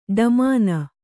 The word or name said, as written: ಢಮಣ